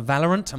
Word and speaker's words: Valorant: But so you mentioned the game earlier, Valorant.